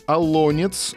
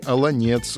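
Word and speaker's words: Олонец: Вместе с ним э, в списке Осташков, Тверская область, Алонец. Это нормативное ударение в русском языке на вторую гласную Алонец. Однако есть и Алонец. Э, так, э, так тоже допустимо, если вы Э, э, житель Карелии.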